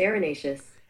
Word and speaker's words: erinaceous: Hey, Google, how do you pronounce E-R-I-N-A-C-E-O-U-S? – That's pronounced arenaceous.